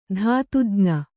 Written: ಧಾತುಜ್ಞ

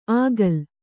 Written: ಆಗಲ್

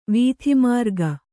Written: ವಾತಮೃಗ

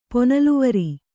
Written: ಪೊನಲ್ವರಿ

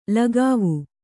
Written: ಲಗಾವು

